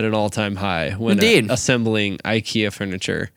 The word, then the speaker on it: IKEA: That, according to the phonetic pronunciation, might be pronounced IKEA. – No, it's not. – That's what I'm looking at. The first syllable is E-E. IKEA.